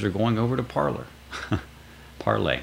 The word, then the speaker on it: Parler: I know I always say it Parler because people are trying to type it. And most people, I hate to have to keep saying you spell it Parler, you pronounce it Parlay.